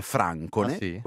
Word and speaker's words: Francone: Tra l'altro credo che la dizione più corretta in realtà sia francone, con l'accento sdrucciolo.